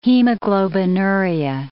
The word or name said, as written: hemoglobinuria